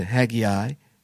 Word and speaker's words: Haggai: Read Haggai, or some people pronounce it Haggai, chapter 1.